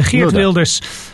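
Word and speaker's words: Geert Wilders: Хирт, ну, по-голландски произносится Хирт Вилдерс, он лидер партии для свободы, ПВВ называется, Партия for the Freiheit, и по-голландски.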